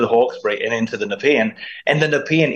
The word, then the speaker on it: Nepean: Now, I'll help you with the pronunciation. It's the Nepean River there.